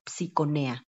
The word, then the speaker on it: Psiconnea: pues están haciendo uso justamente de esta plataforma Psiconea, que se escribe PSICONNEA, Psiconea.